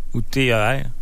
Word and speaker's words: Outer: La saison 2 qui vient tout juste de paraître, qui est Other Banks. Ça s'écrit O-U-T-H-E-R, puis Banks. Ça se passe en... Hein? Other? – T-H-E-R? Ou T-E-R? – T-A-R, peut-être. – Ah, c'est outer.